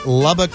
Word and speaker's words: Lubbock: I hope I'm pronouncing it right. Lubbock, Texas. – Oh, I think you got it.